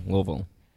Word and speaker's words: Louisville: L-U-H-V-U-L, Louisville.